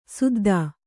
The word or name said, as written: ಸುದ್ಧಾ